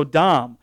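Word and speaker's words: dam (דם): Okay, so dam is how it would be pronounced.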